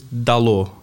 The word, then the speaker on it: Dalot: The right pronunciation is Dalot.